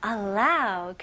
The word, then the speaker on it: allow: 그래서 A로 시작하지만 이 어 발음이 나와야 됩니다. allow.